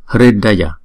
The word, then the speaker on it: Hridaya: Chữ R có dấu nặng này nè thì chúng ta có ba cách đọc. Miền Bắc Ấn Độ thì đọc là Ri, không? Miền Nam Ấn Độ đọc là Ru, một số miền khác đọc là R. Cho nên chữ này chúng ta đọc là Hridaya, Hridaya.